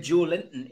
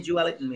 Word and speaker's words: Joelinton: But, you know, my God, you keep saying Joe Elton, mate. – Well, that's how the Portuguese pronounce it. If you listen how Bruno speaks about him, he calls him Joelton, so I'll, I'll take their word for it.